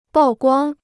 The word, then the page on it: 曝光: bào guāng